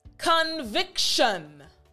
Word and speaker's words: conviction: So instead of T-I-O-N, tion, it's actually shun. So say it after me, conviction.